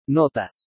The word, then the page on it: NOTAS: spanish